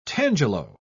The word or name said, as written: tangelo